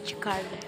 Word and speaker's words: Jakarta: Yes, the pronunciation was Jakarta.